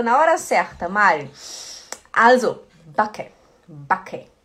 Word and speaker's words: backe: Lembra que a gente estudou na segunda-feira a pronúncia do CK? A gente fala backe, backe, né? O CK ele é rápido. Backe, backe.